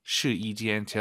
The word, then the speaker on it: 试衣间: 숫자 1이 아니라 여기서는 YI 발음, 试衣 아니고 试衣. 시이지엔. 제가 이렇게 왜 말씀을 드리냐면 한국인 초급자분들이 가장 어려워하는 게 사성담의 일성이에요. 따지아 이 발음을 따지아 막 사성이 일성이 돼버려요. 네, 그래서 시이지엔 확실하게 시이를 확 올려서 발음하셔야 됩니다.